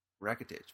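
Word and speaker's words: Rakitić: Ivan Radich. Rakitic. – Rakitic, maybe. Sure.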